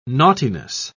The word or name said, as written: naughtiness